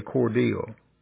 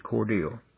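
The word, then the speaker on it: Cordele: You got to say Cordell. Like D-E-A-L, it's not spelled that way, but that's how you pronounce it, Cordell. It's C-O-R-D-E-L-E. But just when you go down that way, just say Cordell.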